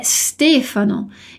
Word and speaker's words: Stefano: A gente não diz Stefano, é Stefano.